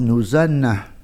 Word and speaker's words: Nos Anem: on prononce Nos Anem, on ne prononce pas le M.